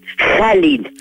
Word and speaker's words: Khalil: Comment vous le prononcez exactement? C'est Khalil Gibran? – Khalil. – Khalil. Khalil. D'accord.